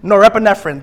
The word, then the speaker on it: norepinephrine: How do you pronounce that word, my medical professionals? Can one person say it to me? Norepinephrine. Norepinephrine, thank you.